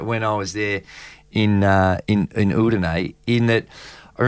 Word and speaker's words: Udine: How do I pronounce that? – Udine. Yeah. – Tell me one short story about how basketball is different in Udine.